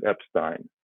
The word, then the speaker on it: Epstein: And I listened to that over and over again, and they kept talking about George Martin and Brian Epstein, which is the way the Beatles would pronounce it, Epstein.